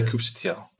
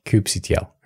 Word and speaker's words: kubectl: I always say Kubectl. – Kubectl. Okay, good.